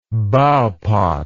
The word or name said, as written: bowpot